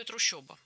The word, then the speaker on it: трущоба: Ну, нам, наверное, не так-то принципиально, как то, что там действительно пишется «о». Как и в слове «трущоба». Хотя слышится очень ёшно, по-моему.